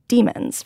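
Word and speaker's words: dæmons: And those animals are called demons, right? Is that the correct pronunciation? – That's right. Yeah.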